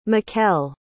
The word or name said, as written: mykel